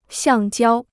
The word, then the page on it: 橡胶: xiàng jiāo